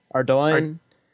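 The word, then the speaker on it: Ardougne: To get to East how do you say that city? – Ardoin.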